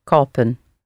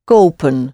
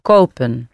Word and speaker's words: kopen: We gaan het kopen. – We gaan het kopen. – Kopen. – Kopen. – Like komen. – That's it. That's right. Komen and kopen. Nice long O sounds.